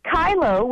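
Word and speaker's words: Kyllo: In Kylo, which is spelled K-Y-L-L-O, V-U-S,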